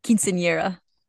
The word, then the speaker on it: Quinceañera: This is a big deal in the Mexican world, and typically it would be called a quincena, and I hope I'm saying that right. – Quinceañera. – Okay, thank you.